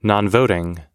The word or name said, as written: nonvoting